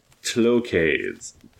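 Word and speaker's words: Tloques: I believe it is Tlokes. Tlokes. – How do you spell that? – T-L-O. – That's me. – Q-U-E-S.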